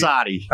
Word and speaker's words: Asadi: Asadi.